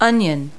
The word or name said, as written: onion